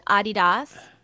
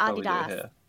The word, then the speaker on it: Adidas: Fun fact, did you know that Adidas is pronounced Adidas? – Really? Adidas? – It's German. Yeah, it's German. It's Adidas.